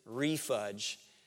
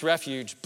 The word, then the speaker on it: refuge: And when he got to that word, he pronounced it refudge. I thought, buddy, that is funny. The word is pronounced refuge, but I decided to share that with all of you.